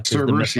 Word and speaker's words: Cerberus: So if you like that kind of stuff, and you had me at Cerberus, if I'm saying that right. – Cerberus, yeah.